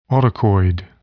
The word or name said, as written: autacoid